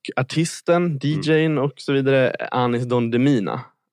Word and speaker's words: Anis Don Demina: Så här, om man inte känner till honom, Anis Don Demina är hur det uttalas.